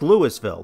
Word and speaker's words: Louisville: It is pronounced Louisville, named after the King of France for his contributions to the American cause during the Revolution.